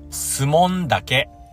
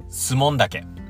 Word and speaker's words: 守門岳: きますねこんな感じでね 1 年もあっという間に終わるんじゃないかなと思いますけども、 3 月 5 日は、えー、新潟県のスモン岳というとこに行ってきました。スモン岳。ね、えー、守門、守る門と書いて、スモン岳ですね。このスモン岳は、えー、 3 つのピークを総称して、スモン岳と呼ばれてます。